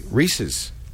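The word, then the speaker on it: Ries: Am I saying that right? Reese? – Yeah. Like the candy. – Yeah, like the Reese's.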